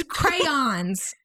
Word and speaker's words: crayons: No, it's crayons. It's yeah, two crayons. Syllables. Crayons. You say it how it's spelled.